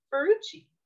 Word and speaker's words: Ferrucci: And to do that is our guest host, Loretta Ferrucci.